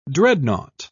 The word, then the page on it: dreadnought: British English